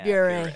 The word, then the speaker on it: Bure: Bure. I, it, I always say it Bure. rhymes with puree.